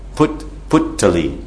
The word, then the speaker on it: puttali: Because the Bengali is P-U-T-T-A-L, long I. Puttali.